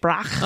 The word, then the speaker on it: Brach: I'm not exactly sure how to pronounce it. – How's Brach. – It spelled? – B-R-A-C-H, Brach. – Oh, okay, that's good. – Brach. – I think you got it.